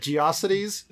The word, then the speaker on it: GeoCities: I heard a podcast where they had, like, one person was pronouncing it Geocities and the other person was pronouncing it Geocities. – But it's gotta be Geocities.